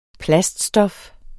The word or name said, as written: PLASTICSTOF